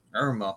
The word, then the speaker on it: Irma: Irma. Which one's Irma? – So she rhymes with sperma.